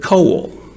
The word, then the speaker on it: kol: That term all things is translated from the Hebrew word kol. You can spell it K-O-L.